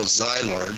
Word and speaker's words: Szilard: And actually, when Einstein and writes this famous letter to FDR in 1939, quoting Leo Szilard,